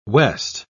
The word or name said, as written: west